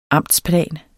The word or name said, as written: amtsplan